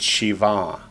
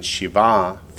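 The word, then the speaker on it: Shiva (שבעה): If it's the masculine column, it's Shiva. The accent's on the last syllable. If it's the feminine form, Sheva, the accent's on the first syllable. So if it's masculine, Shiva, feminine, Sheva.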